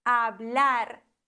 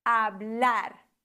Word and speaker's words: hablar: Zum Beispiel doch bei den Infinitivformen. Hablar. Da haben wir ein L und dann wird die letzte Silbe betont. Hablar.